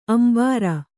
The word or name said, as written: ಅಂಬರಿ